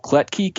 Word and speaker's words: Klettke: So you can find me on Twitter at Joel Kletke, K-L-E-T-T-K-E, sort of weird last name, and you pronounced it beautifully, so thank you for that.